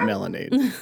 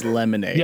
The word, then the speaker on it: lemonade: And then Talia very like just is stuck and like it's like it's not dad you said it wrong, it's not melonade, it's lemonade.